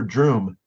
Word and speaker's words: Droom: and he's rolled out first as Dr. Droom, not Dr. Doom, but with a rhyming with broom, Dr. Droom.